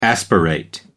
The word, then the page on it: aspirate: American English